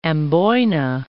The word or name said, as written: Amboina